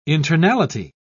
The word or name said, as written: internality